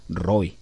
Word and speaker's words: Roi: Así que cuando usted dice El Roi, es una palabra que está deletreada en una forma poco corriente. Se pronuncia Roi.